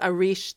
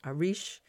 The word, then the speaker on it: Arís: All right, tell us about Arish Theater. – Arish, which is a Gaelic word that means encore, spelled A-R-I-S, but not pronounced Aris, it's pronounced Arish, is about to enter, I believe next year, is its 10th anniversary.